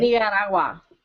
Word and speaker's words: Nicaragua: You got to like roll the Nicaragua. – D. – Bit, right? Nicaragua. – Yeah. Roll the D and get rid of the G or something like that.